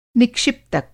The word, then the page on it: निक्षिप्तक: Hindi